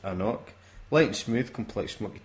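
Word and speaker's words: AnCnoc: Hello, and this drams is an Anknock. – Anknock. – Anknock. – Anknock. – It tells you how to pronounce it. I still got it wrong on the bottle.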